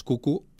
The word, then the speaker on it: Cuckoo: Por cierto, sí, la película es Cucu, pero se escribe Q-C-U. C-K-O-O.